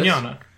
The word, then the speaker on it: Mignogna: That's not how you say his name, is it? – Yeah, Vic Mignogna.